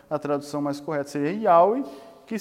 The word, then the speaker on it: Yahweh: Aquele Senhor que o Jeová, né, que não é a tradução correta, é Yahweh, né, que se pronuncia até assim, Yahweh, mas isso é uma longa discussão.